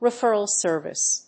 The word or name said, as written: referral service